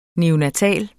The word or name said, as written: neonatal